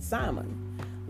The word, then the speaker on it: salmon: before I even realized that the L was silent, I was saying salmon, like most people still say salmon.